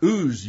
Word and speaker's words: Uz: But that's the name of the city is Uz? – Uz, U-Z.